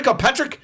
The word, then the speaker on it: Ocasek: You know, it's like, yeah, because, you know, when Rick Ocasek or Kasek, I think you say it Rick Ocasek. – Yeah, Rick Ocasek. – Racist, you're saying it wrong. – You're saying it's wrong. It's Rick Ocasek.